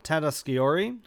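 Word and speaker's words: Tatasciore: How do I say that? – Tata Sciori.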